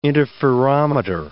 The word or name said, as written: interferometer